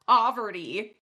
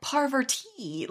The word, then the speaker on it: Parvati: I must discuss the mispronunciation of Parvati's name this episode because Kate calls her Poverty. Like, so clearly it's like Poverty. – Unfortunately, I didn't even notice because in my head she's Parmesan cheese. So, like, didn't even notice. It didn't even come up in my head. Nope. – And then Phaedra always pronounces it like Parvati. Like, she emphasizes the T.